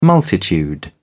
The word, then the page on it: multitude: British English